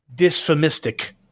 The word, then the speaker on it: dysphemistic: So that is dysphemistic swearing. – Okay. – That was good. Yeah. That was good pronunciation. That was spot on.